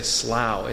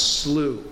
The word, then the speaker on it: Slough: My wife keeps telling me whenever I say slough, it's slough. So slough.